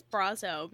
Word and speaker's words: Brasso: And Cassian's friend, is it Brasso? Brazo? – I think it's Brazo because Brazo. It rhymes with Grosso.